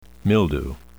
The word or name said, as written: mildew